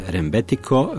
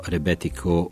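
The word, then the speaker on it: rebetiko (ρεμπέτικο): Nešto više o rebetiki nešto kasnije, naravno neki će reći rembetiko, jer se tako piše i na grčkom, ali Grci će to izgovoriti kao rebetiko u jednini, rebetika u množini.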